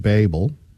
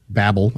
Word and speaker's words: Babel: The first was the Tower of Babel. Babel, I guess Babel.